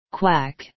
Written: quack

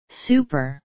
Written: super

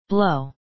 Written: blow

